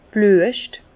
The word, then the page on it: d' Blüescht: Bas Rhin; Herrlisheim